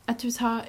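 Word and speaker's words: Étretat: I don't know how to say this. Etretat? – Etretat.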